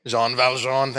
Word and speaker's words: Jean Valjean: And the rest of the story is Jean Valjean, or how do you say his name? Jean Valjean, thank you.